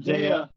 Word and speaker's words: Daya: How do you say it? – Daya. – Daya, yeah, thank Daya you.